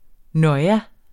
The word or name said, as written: noia